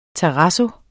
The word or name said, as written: terrazzo